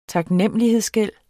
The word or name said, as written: taknemmelighedsgæld